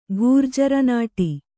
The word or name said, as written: ಘೂರ್ಜರನಾಟಿ